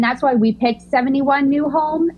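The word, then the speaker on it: Nyhavn: Nyholm is actually how Nyhaven is pronounced. – Oh. – And so, but I was calling it Nyhaven for a very long time as well.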